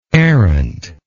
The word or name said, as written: arendt